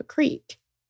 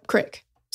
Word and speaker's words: creek: They were at the bottom of a creek. – You're not pronouncing that right. – Oh, creek. Excuse me.